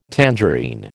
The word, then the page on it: tangerine: American English